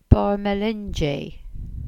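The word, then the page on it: Barrmelinji: Cornish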